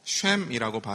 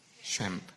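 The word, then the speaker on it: 쉼이라고 (שם): 여러분 이름은요 히브리어로 쉼이라고 발음을 합니다. 쉼 뭐라고요? 쉼.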